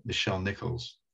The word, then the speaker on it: Nichelle Nichols: And I think that we should mention, I hope I'm pronouncing her name right, Nichelle Nichols.